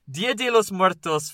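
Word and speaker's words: Día de los Muertos: Dia de los Muertos God damn it, that's not the right way to say that. Dia de los Muertos finds its origins long before the arrival of European settlers.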